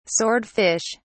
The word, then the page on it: sword fish: British English